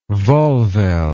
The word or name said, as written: volvelle